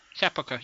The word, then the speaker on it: sepulcher: I never could pronounce that word. – Okay. – Sepulcher. – Sepulcher. Yes, it's sepulcher. Uh-huh. – Yeah, that's it. That's it.